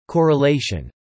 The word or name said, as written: correlation